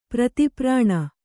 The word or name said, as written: ಪ್ರತಿಪ್ರಾಣ